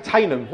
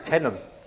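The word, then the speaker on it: Teynham: It was like that with Tainham, wasn't it? Yeah, yeah, yeah. If I've never been there, I don't know how to pronounce it. Tenham. Tenham. Not Tainham. It is spelled Tainham.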